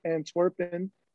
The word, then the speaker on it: Antwerpen: I lived in Antwerpen is the correct pronunciation, but I loved everything about the city.